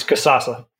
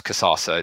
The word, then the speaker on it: Kasasa: You're both joined by Chief Marketing Officer at Kazaza. Have I said that right? – Pretty close. It's Kazaza. – It's close. You're in the ballpark, right? – Yeah, it's more like a sauce, Kazaza.